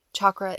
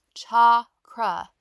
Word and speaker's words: chakra: By the way, chakra is traditionally pronounced cha-kra, like the cha and chai, which is closest to the Sanskrit.